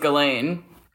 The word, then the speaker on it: Ghislaine: Is it Ghislaine? How do you say her name? – The French way is Ghislaine.